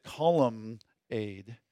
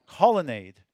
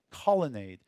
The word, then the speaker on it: colonnade: And I always pronounced it columnade because it's got columns, right? But that's not how it's pronounced. That's silly. It's I can't even say it now. Colonnade. Colonnade. It's just N's. There's no M in there, right?